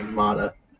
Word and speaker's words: Mata: Do you like that hard T on Mata? – I pronounce it like Mata.